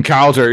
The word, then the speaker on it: Cowser: I see Colton Cowser. He's a guy. I'm saying his name correctly, I would imagine. Cowser, right? – Yeah, yep.